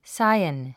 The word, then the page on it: scion: sáiən; サイエン